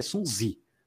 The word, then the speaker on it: Sun Tzu: Eu, se eu, salvo engano, a pronúncia em chinês é Sun Zi, né? mas eu vou chamar de Sun Tzu que é mais fácil Sim, é, nós entendemos.